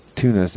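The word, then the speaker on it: Thunnus: Thunus is actually pronounced tunas.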